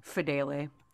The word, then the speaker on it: Fidele: How do you say his name? – Fidele. That's Fidele. How you said it.